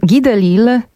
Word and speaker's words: Delisle: Guy Delisle pisze się D-E-L-I-S-L-E.